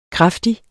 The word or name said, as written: kraftig